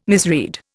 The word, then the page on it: misread: Indian